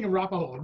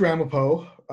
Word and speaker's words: Ramapo: Rampart. I always say it wrong. What is it? Okay. – It's the East Ramapo Central School District. – I'm always saying Arapaho.